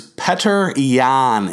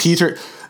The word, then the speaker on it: Petr Yan: But the guy who might be the dude at 135 that could give Henry a great fight um, is Petr Yan, is how you say. It. I yes. thought it was Peter.